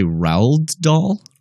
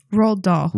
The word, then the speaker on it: Roald Dahl: You say Roald Dahl? – Roald Dahl. – Roald Dahl.